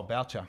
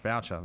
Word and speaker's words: Boucher: Oh, Boucher. – Boucher, that's the one.